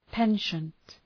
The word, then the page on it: penchant: ‘pentʃənt